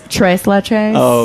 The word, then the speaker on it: tres leches: You're not pronouncing it right. Because at Chardonnay's, it is tres leches.